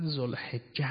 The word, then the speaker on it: ذوالحجه: آقا بند فرمید ذوالحجه، ذوالحجه. اینجا مثل این که اشتباه در تلفظ است که در حقیقت ذوالحجه است و ذوالحجه است، نه حجه. اینجا با کسره باید باشه درستش.